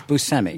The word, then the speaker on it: Buscemi: Now, Now, is it how do you pronounce it? You said it the way I say it. – Yeah. – Buscemi.